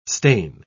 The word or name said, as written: stain